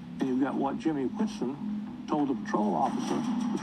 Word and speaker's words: Whitson: I said Watson. It's Whitson. Sorry. Because W-H-I-T-S-O-N. So again, it's spelled W-H-I-T-S-O-N. So it's Whitson.